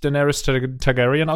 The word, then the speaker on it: Daenerys Targaryen: Wie findest du, habe ich Daenerys Targaryen ausgesprochen als Fantastisch.